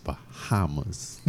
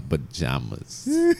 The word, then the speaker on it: Bahamas: J in Spanish is ha. A is pronounced H. – Yes. – So that's why they change it from J to H. Bahamas, not Bajamas.